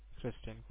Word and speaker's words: Christen: Your first name is C-H-R-I-S-T-E-N, right? – Yes, Kristen.